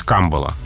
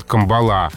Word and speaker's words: камбала: Да, и ударение эталонное, соответствующее языку источнику, на первом слоге. То есть камбала. Но, видимо, просто неудобно нам произносить это слово с ударением на первом слоге. А, видимо, хочется нам сдвинуть туда ударение ближе к концу слова, поэтому вариант «камбала» существует. Он раньше запрещался, но сейчас он расценивается как допустимый. То есть это уже не ошибка, но и не эталон.